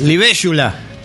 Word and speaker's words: Libélula: Pronúncielo, amigo, ¿cómo es? – Libélula. ¿No? – Y sí.